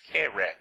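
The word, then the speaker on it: kerah (קֶרֶב): It's actually transliterated Q-U-R-E-V, but it's pronounced kerah.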